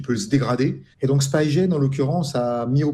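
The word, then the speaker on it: SpyGen: Alors ce projet, il est aussi en partenariat avec Spigen. Est-ce que je l'ai bien prononcé ? – Spigen. Comme les espions.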